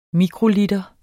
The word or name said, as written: mikroliter